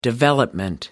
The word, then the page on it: development: American English